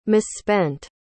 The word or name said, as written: misspent